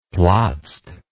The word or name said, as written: plotzed